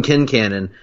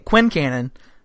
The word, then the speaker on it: Kincannon: And I had been pronouncing it Quincannon for the entirety of our friendship until the show came along and informed us we were idiots.